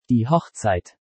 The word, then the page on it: Die Hochzeit: German